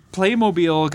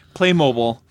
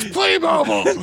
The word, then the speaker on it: Playmobil: So anyway, Playmobil got moved to... – I'm sorry, what? – I'm sorry. I apologize for the mispronunciation. Playmobil. – Dad, it's Playmobil!